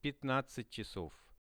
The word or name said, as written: 15-chasov